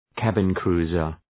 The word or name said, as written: cabin-cruiser